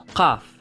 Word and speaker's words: qaf: It is basically I'll try and pronounce it, qaf, qaf. It's basically a K, like a K, like a K sound, but it goes way back in your mouth.